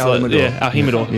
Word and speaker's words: El Jimador: They don't pronounce the J, right? So it's El Jimador.